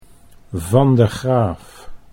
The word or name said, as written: Van de Graaff